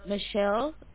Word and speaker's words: Michelle: Michelle, M-I-C-H-E-L-L-E. Last name, I'm going to spell it for you. B as in Bravo, A-C-K, H as in Hotel, U-S.